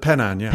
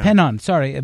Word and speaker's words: Penan: the Penan, yeah. – Penan, sorry, this nomadic indigenous people in Borneo.